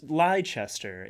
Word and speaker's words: Leicester: So, Leicester? It's Leicester. It's just it.